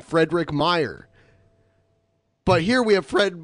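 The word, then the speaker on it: Meijer: She like stars some scuff in the background, and it's spelled M E I J E R, I think. It's Meyer with a J.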